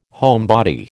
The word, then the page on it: homebody: American English